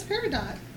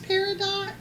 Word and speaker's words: peridot: Isn't it pronounced peridot? It's peridot. Is that how you pronounce it? Okay. So peridot, y'all. but it's spelled with the T at the end, so I said peridot.